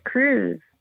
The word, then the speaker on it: Cruz: My name is Cruz, like Santa Cruz.